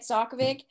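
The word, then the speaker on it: Sakavic: I've started The Foxhole Court All for the Game series by Nora Sokovic. I think that's how you say it, Sokovic.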